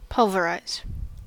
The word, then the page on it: pulverize: American English